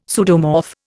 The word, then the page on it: pseudomorph: Indian